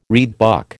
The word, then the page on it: reedbuck: American English